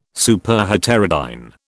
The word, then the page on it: Superheterodyne: British English